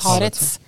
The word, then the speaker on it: Haaretz: Hårets journalist Gideon Levi. Han har jo vært veldig kritisk. Han jobber i Hårets, er det ikke sånn det ah, uttales? Haaretz.